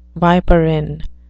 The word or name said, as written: viperine